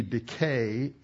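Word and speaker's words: decay (δικαιόω): and it's the root, the D-I-K, and then there's a long E, decay, in the Greek, which is your root.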